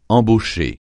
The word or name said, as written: embaucher